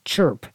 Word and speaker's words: CRHP: It was abbreviated CRHP, but people pronounced it chirp as if the H and the R were reversed.